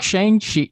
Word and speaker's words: Shang-Chi: Let's talk Shang-Chi. Dang, I thought I was going to go the whole episode. – That's the American pronunciation. You can pronounce it that.